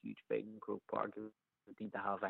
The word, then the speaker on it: Croke Park: Croke Park.